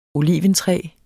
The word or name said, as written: olea